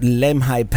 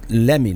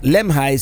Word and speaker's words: Lemhi: I mean, we wanted to cross this area where Lewis and Clark for the first time crossed the Continental Divide, and that's I believe it is pronounced Lemhi Pass, although the Google lady pronounces it Lemmy, like Lemmy Pass, but Lemhi seems to be the consensus.